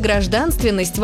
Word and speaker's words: гражданственность: Постановка ударения в слове «гражданственность» вопросов не вызывает. Любой из нас произнесет его с ударением на второй слог.